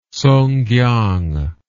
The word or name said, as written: Sungkiang